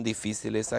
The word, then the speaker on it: difíceis: Por que as coisas são tão difíceis aqui? – And again, interrupt, I think that's a good example to hear that difference between Spanish and Portuguese.